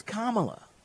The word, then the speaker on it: Kamala: They know they're saying it wrong, and they do it on purpose. Kamala Harris. We know it's Kamala.